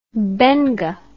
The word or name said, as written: benga